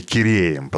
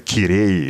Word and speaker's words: Кирей: Одни говорят, будто Гераем, точнее Киреем, поскольку Герай – это позднее, уже османское произношение, принятое уже в период зрелого крымского ханства. А вот в ордынской речи арабское написание имени нашего героя читалось как Кирей или Гирей.